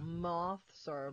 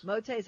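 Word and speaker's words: Mauthe's: Motes. Motes, that how they say it? – M-A-U-T-H-E, apostrophe yes.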